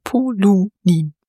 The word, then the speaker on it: Polunin: Da ich mal gefragt werde, wie man den Nachnamen ausspricht, Betonung auf dem U, also Polunin.